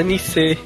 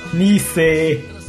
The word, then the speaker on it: Nice: Por favor, pronúncia correta, Nice. – Nice.